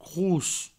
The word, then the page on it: cros: crous